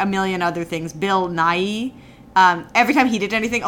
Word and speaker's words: Nighy: When we saw it the first time, every time Bill Nighy, just to be clear, that's Bill Nighy, two syllables.